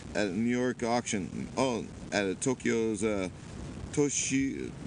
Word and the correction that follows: Toyosu: Toyosu Fish Market on on Sunday.